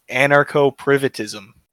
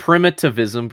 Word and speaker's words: primitivism: I thought it was anarcho-privitism. – Yeah, something like that. Yeah, primitivism. But definitely... – No, it's primitivism because you're becoming a primate.